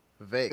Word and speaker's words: Vivek: Vivek. – Is how they say. – That's Vivek, yeah.